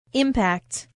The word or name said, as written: impact